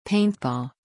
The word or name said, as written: Paintball